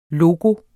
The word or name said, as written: LOGOERNE